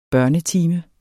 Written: børnetime

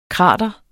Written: krater

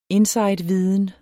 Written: insideviden

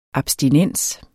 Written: abstinens

